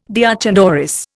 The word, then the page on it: deoch an doris: Indian